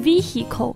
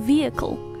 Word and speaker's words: vehicle: 车辆、交通、工具这个词中间的 H，它同样是不发音的，所以大家不要，尤其还有同学会把它着重，会读成 vehicle，这样子就会听起来有点奇怪啊。正确的读法呢，它不发音，或者说至少要把它弱化，会读成 vehicle。